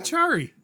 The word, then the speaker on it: Achari: Achari? Is that the way you pronounce it? – Achari. – Okay. – Achari. – Okay, I'll go with your pronunciation. So Achari.